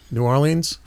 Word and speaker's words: New Orleans: New Orleans? – Mm-hmm.